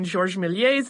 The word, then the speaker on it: Georges Méliès: I did have to laugh, though, when someone had emailed us and asked why we did not pronounce the R in Georges Méliès's name, but we did pronounce the R in the Lumière brothers' name.